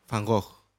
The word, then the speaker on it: Van Gogh: Van Gogh.